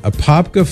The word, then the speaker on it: Apopka: And Jeanette in Apopka. I hope I'm saying it right, Mary. Does that sound right? Apopka, Florida. – That sounds perfect to me. – Right on. Apopka, Florida.